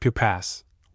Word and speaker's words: Pupas: Her name was Marie Pupas, but no one thought of calling her anything but Pupas, with emphasis on the first syllable and sibilance on the last.